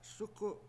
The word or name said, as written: sUku7